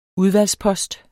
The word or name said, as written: udvalgspost